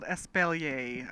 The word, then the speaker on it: espalier: It's called espalier. – I believe is the correct pronunciation.